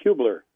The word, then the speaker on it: Hubler: It's pronounced Hubler.